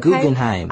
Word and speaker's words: Guggenheim: Tour the Guggenheim? Did I say that right? – Mm-hmm, Guggenheim.